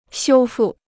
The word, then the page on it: 修复: xiū fù